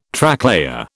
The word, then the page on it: Tracklayer: British English